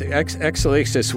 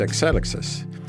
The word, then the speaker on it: Exelixis: It's Exelixis, which is properly pronounced Exelixis. – Ah. – It's a Greek word, and it means kind of evolution or forward progress, so an apt name for a biotech company.